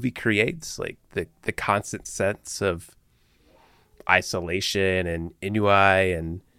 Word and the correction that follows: ennui: Is it inui? Inui? How do you say that? Ennui. – Ennui. I wasn't even close.